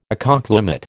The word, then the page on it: ecoclimate: American English